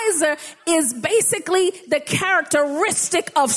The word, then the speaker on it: Ezer: And the Hebrew word for help or helper is Ezer. It's spelled E-Z-E-R, but pronounced A-Y-Z-E-R.